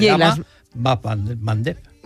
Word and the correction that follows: Bab el Mandeb: Bab el Mandeb, esa me la voy a apuntar. – Cuesta un poquito pronunciarlo, por eso en Occidente se dice el Mandeb. – Bab el Mandeb.